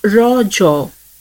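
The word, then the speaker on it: Raja: The festival is natively pronounced as rojo, a local term that means menstruating woman.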